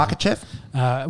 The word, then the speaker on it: Makhachev: Makhachev.